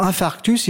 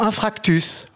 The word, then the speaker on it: infarctus: Hein, pour infarctus, ils diront infractus.